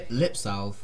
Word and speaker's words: lipsalve: But it's actually lipsalve because you're salving the sorbet.